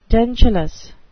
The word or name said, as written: dentulous